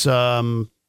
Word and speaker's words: StOAKed: That's StOAKed. That's what I said. Yeah. – Yeah, you said it right.